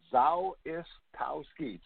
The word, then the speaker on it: Zawistowski: It, Rory, it's all syllabic. Zawistowski, just like it's spelled out, but you can call me Tom Z.